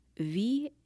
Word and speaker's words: valetudinarian: The word is valetudinarian.